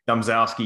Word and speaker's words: Domzalski: Domzalski, you nailed it. Domzalski.